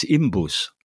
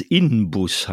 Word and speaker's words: Inbus: Und das ist ähnlich wie, was ich auch immer falsch sage, ist Imbus. Der Imbusschlüssel. – Ja, machst du? – Ja. Ja, weil ich weiß, dass es Inbus heißt. Ja. Aber es spricht sich Imbus leichter, weil M und B wirklich beides sogenannte Bilabiale sind. Ne? Inbus ist viel schwieriger.